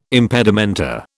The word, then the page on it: impedimenta: British English